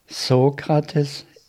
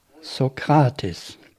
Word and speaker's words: Sokrates: Wir sagen Sokrates auf Englisch, aber dann sagte Swami im Interview, Sokrates ist nicht korrekt. Die richtige Aussprache sei Sokrates. – Even once he corrected the pronunciation, we say Socrates in English. But then Swami said in the interview, Socrates is not correct. The right pronunciation is Socrates.